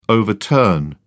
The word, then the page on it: overturn: British English